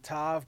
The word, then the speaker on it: Tav: You know, people, it's spelled T-A-V. Some people pronounce it Tav, but in the Hebrew it's Tav.